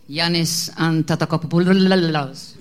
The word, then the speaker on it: Giannis Antetokounmpo: Giannis Antetokounmpo.